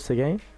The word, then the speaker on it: Seguin: My name is Caleb Seguin.